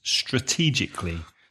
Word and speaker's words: strategically: You do it strategically. So how many syllables? Strategically, four syllables. Mm-hmm. And the stress is on the second syllable.